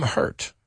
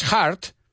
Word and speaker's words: Hurt: Now remember, esa U se pronuncia como si fuera una E. El nombre de este actor, que también significa dolorido. William Hurt. Say it with me. No es heart. Heart es corazón.